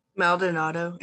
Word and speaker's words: Maldonado: Maldonado. It's a Puerto Rican last name. My father is Puerto Rican.